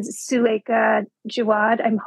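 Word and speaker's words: Suleika Jaouad: And Suleika Jawad, I'm saying her name correctly, her Between Two Kingdoms was the first memoir that I read, which I thought was exquisite.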